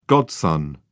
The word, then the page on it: godson: British English